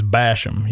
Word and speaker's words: Basham: Just like my name's Basham, you got to say it like with that real redneck kind of sound.